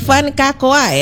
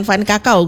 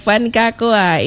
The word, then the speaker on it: Van Kakoa: Ini Van Kakoa ya? – Betul. – Bacanya kayak gitu ya? – Iya. – Oke, bukan Van Kakao bukan ya? – Bukan. – Oke, baik, Van Kakoa.